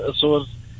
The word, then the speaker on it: Açores: Exatamente, tal e qual, conforme se escreve Açores, as ilhas, na verdade.